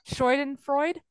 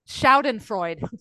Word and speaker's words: Schadenfreude: Schadenfreude. – Schaden. S-C-S-C-H-A-D. Okay. – Matthew's trying to tell me from the other room with my headphones on. – I thought he might be. – Schadenfreude.